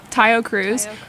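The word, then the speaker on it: Taio Cruz: Tayo Cruz.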